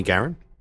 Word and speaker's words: Guerin: Again, we have Green Rainbow Party, Jamie Garin.